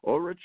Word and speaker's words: Ulrich: So, Ulrich, you on with us? – Yes.